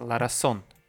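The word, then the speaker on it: razón: Ларасон. У них Z читается, у них как S читается. Ларасон.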